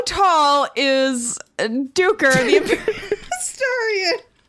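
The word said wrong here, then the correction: Duiker: Also, it's Dwicker.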